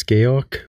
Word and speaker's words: Georg: which is Georg. You spell it like George without the E at the end and one other number and then SC.